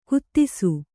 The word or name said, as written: ಖೇದಿಸು